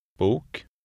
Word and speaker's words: bok: Here it resembles the sound in boat. – Bok.